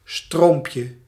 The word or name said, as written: ruisselet